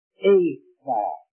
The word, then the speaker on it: Ephah: And he said, and by the word, wait, the word is pronounced Ephah, but it's easier to say Ephah or Ephah. It is Ephah, and it is in our language.